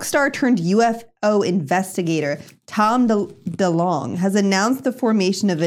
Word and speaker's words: DeLonge: DeLonge announces creation of fringe science research team.